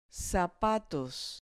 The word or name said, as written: zapatos